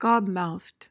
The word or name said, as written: gobmouthed